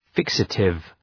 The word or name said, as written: fixative